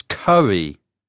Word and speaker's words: Curry: Is that C-A-R-E-Y? – No, it's not Carey. It's Curry, with a double R, C-U-R-R-Y.